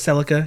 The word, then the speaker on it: Celica: And the girl's name was Celica, because I said it wrong last time.